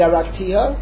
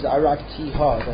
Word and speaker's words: araktiha: One of them says ani araktiha. Okay, the pronunciation of the word is araktiha. The hey has a comment under it.